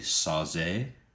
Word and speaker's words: Sauget: I was calling it Sauget Wind, and it's actually Sauze.